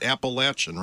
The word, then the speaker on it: Appalachian: Home of Appalachian State University. And did I say it right? Appalachian, right? – Yeah. Appalachian. There you go.